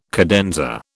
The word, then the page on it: cadenza: British English